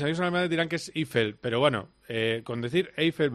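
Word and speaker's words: Eifel: ¿Es el gran premio de Eiffel o Eiffel? ¿Cómo se dice? – Eiffel. – Eiffel. – Eiffel, sí, pero no confundí con la torre.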